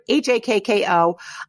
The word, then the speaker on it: Hakko: So we snagged an interview with Danielle Citrullo, Doctor of Pharmacy and Technical Services Manager for Kiowa Hako. I think I'm pronouncing it right. It's Kyowa, new word, Hakko.